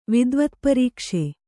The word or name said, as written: ವಿದ್ವತ್ಪರೀಕ್ಷೆ